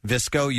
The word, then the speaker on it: VSCO: And that's spelled V-S-C-O. – Yeah. – Visco, you've heard of this now? – I have.